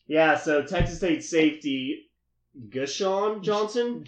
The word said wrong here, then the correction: Jashon: Jashon. – What? – Hey, I'm Jashon. – Hey, Jashon. – Did you say Gashon Johnson? – He did say Gashon. – That is not how it's pronounced, okay? – Jashon Johnson.